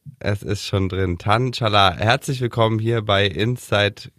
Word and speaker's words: Çalar: Hier ist äh, Tan Chalar. – Oh, wie ein Türke ausgesprochen von ja, da, ne? – Simon ja.